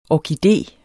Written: ORKIDE

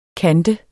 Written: kant

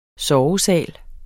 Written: SOVESALEN

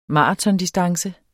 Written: maratondistance

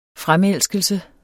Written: fremelskelse

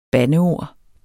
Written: bandeord

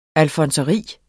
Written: alfonseri

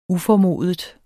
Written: uformodet

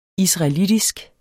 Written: israelitisk

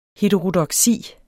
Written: heterodoksi

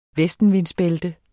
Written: vestenvindsbælte

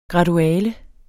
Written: graduale

